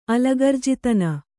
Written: ಅಲಗರ್ಜಿತನ

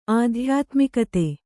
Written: ಆಧ್ಯಾತ್ಮಿಕತೆ

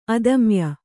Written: ಅಧೋಮಾಯೆ

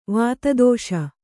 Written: ವಿತ್ತಾಧೀಶ

